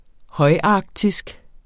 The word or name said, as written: højarktisk